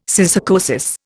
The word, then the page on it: syssarcosis: Indian